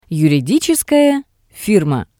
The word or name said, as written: yuriditseskaya firma